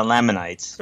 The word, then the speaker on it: Lamanite: I still screw that one up, too. I catch myself, though. I'll say it wrong, and I'll be like, oh, God, all right, don't ever get mad at me. It's Lamanite, sorry. – Yeah, that would never have occurred to me to pronounce it Lamanite. – It looks like the word laminate. It just does.